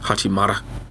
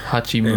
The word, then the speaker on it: Hachimura: Hachimara. How you say his name? I can't say it. Hachimura.